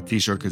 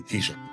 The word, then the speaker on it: Taoiseach: Um, the way I say it is uh, T-shirt as well. Um, uh, it's like one of those things, if you start thinking about it a bit too much, everything starts to get a bit ridiculous in your head and you start to doubt everything. But uh, generally um, accepted pronunciation would be T-shirt.